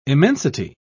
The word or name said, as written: immensity